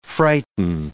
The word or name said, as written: frighten